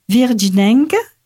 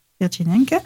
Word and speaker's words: Virginenques: Frédéric Mistral crée les Festes Virginenques, c'est bien prononcé ? – Oui. – Virginenques, pour inviter les adolescentes à porter le costume.